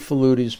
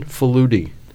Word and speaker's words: Faludi: The Hungarian way of saying it is Faludi, but I grew up in a very Italian neighborhood, so it became Italianized, Americanized at an early age. The first thing I learned is I was mispronouncing my own last name.